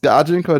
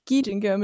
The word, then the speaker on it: Gijinka: Heißt es Gajinka oder Gijinka? – Gijinka mit I. Ich habe es seit drei Jahren, drei Jahren falsch gesagt.